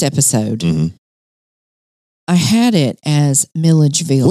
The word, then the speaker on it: Milledgeville: I said Midgeville. – Midgeville. – This is what I'm now calling syllable gate. I left all out right. an entire syllable.